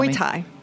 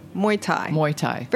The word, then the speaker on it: Muay Thai: Tell Muay Thai. – Me. – Muay Thai. Very close to Mai Thai.